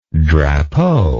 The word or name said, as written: drapeau